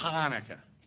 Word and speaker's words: Hanukkah: Or more correctly pronounced, Hanukkah is the way it's supposed to be said.